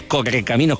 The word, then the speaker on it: correcaminos: correcaminos con R fuerte, que yo lo digo mal, y el mito de Sísifo, ¿no?